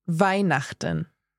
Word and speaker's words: Weihnachten: Weihnachten.